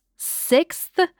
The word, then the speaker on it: sixth: Because this is Doyle's sixth, you gotta pronounce that TH at the end.